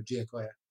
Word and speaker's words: Giacoya: I usually assumed it was Giacoya. – No, I think it's actually multiple syllables in there, Giacoya.